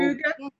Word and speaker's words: hygge: Hygge.